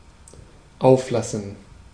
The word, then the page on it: auflassen: /ˈaʊ̯f.la.sən/